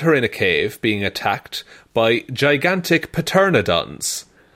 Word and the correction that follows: pteranodons: I think it's actually pronounced pteranodons.